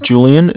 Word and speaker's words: Julian: All right, my name is Julian. It's J-U-L-I-A-N. And I'm calling from Bank of Cardiff. It's spelled C-A-R, D as in David, I-F-F as in Frank Frank.